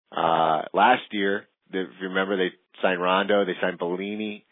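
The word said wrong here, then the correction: Belinelli: I'm mispronouncing the name. Bellinelli,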